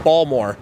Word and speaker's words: Baltimore: And then there's Baltimore. – How would you pronounce the biggest city in Maryland? – Baltimore, right? – Balmore. There's no... – Balmore? – The T is silent.